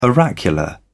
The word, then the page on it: oracular: British English